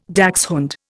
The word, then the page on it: dachshund: Indian